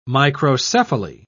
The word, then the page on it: microcephaly: British English